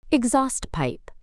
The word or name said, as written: exhaust pipe